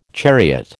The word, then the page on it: chariot: American English